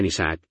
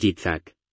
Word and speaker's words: Yitzhak: Yitzhak significa risa y ustedes lo pronuncian Isaac, pero en realidad es Yitzhak.